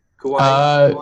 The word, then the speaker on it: Kauai: Kauai.